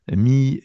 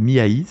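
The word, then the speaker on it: Mihaly: Je l'appellerai juste euh, Mi, Voilà, Miaï, c'est son prénom, Miaï.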